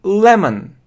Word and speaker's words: lemon: Смотрите, это не «о». На конце не «о», не «lemon». Не lemon, а lemon. Это звук n, n. Это как слабое e, n, n. Слабое e, lemon, lemon, lemon.